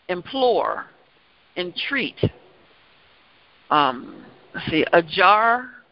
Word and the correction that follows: adjure: not pronouncing this right. Adjure. A D is in David, J U R E.